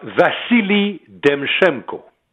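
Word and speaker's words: Vasily Demchenko: Le Canadien est allé chercher un gardien de but en Russie, Demchenko, Et pour bien le prononcer, son nom, Martin McGuire se joint à nous. – Bonsoir, Ron. Vassili Demchenko.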